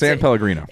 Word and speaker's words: San Pellegrino: I think it's Pellegrino. – Okay. With an S? San Pellegrino? – Let me see it. – San Pellegrino? – Yes, anyways, San Pellegrino.